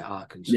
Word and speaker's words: Arkansas: Yeah, Arkansas.